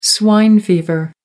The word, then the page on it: swine fever: American English